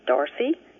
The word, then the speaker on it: Darcy: Hello, my name is Darcy Pattison. It's easy to say Darcy Pattison, but it's hard to spell.